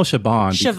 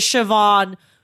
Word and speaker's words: Siobhan: That is Siobhan... Why is there a B in there? Why is because the B is spelled like is pronounced like a V. BH is like a V, right? In Celtic Irish.